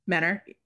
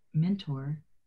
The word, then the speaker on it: Mentor: There is a T in there, and I over accentuate the T. Maybe it's because I have so many in my own name. But when you said that, I was like, Menor. – Yeah, they don't pronounce the T.